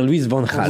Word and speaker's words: Van Gaal: Louis Van Gaal, il faut dire Louis Van Gaal.